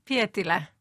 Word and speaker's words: Pietilä: Do Mari and Yona, the two women here, resemble your aunt and her companion, the artist Tuulikki Pietilä? – I pronounced the Finnish name okay. – Yeah, Pietilä.